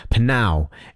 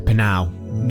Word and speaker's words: Pnau: And by this artist, I always struggle with the pronunciation of this one. Panow is how I say it. You know, P-N-A-O. How? What did I say? P-N-A-U. Not P-N-A-O.